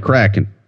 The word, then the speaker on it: Kraken: Yeah, the movie went Kraken. – All right, well, I was wrong then.